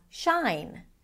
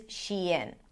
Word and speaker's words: Shein: And people tend to pronounce it as shine, as in shine on, you crazy diamond, shine. So technically, because this is vastly used as well, I guess you can use it, but just so you know that the proper pronunciation is Shein.